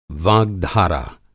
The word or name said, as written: वाग्धारा